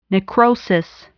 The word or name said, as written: necrosis